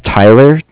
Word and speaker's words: Tyler: My name is Tyler, T-Y-L-E-R, and I'm calling with Cardiff. It's spelled C-A-R. D-I-F-F.